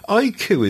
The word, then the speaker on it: iQOO: You see, iQ is something we've just never seen in the East, in the West at all, as far as I'm aware. That's the one that's spelled IQOO.